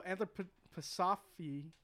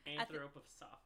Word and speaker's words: anthroposophy: So anthroposophy... how do you fucking say that word? – Anthroposophy.